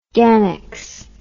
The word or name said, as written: gannex